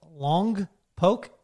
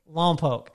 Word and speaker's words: Lompoc: And she's like, Lompoc? And I said, no, Lompoc.